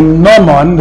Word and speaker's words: gnomon: Now, if we erect a stick in the ground, and we look at its shadow, this stick is called a gnomon, the G is not pronounced,